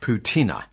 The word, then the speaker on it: patina: Well, it's spelled P-A-T-I-N-A. You pronounce it poutina.